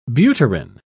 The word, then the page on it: butyrin: British English